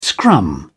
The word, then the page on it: scrum: British English